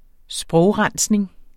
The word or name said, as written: sprogrensning